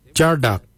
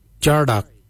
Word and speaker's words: djardag: Jindal is spelled D-J-E-N-D-A-L, Jindal. Then we'll look at Jardak. Jardak is grey, Jardak. Jardak is spelled D-J-A-R-D-A-G, Jardak, grey. We'll now look at the word for brown, Jittery. Jittery is spelled D-J-E-R-D-A-R-I, Jittery, brown, Jittery.